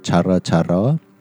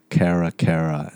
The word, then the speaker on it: Cara Cara: Is the thing on Instagram is called Chara Chara. C-A-R-A-C-A-R-A. But my therapist tadi sebut and dia kata Kara Kara. And I'm like, oh, so that's how you pronounce it. I thought it was Chara Chara.